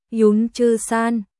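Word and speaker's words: Yún Zhī Shān: IZ, Vân Chi Sơn, Vân Chi Sơn đọc là Yún Chư San, IZ là ba chữ cái đầu của phiên âm.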